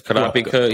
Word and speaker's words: Kurapika: It's Kurapika.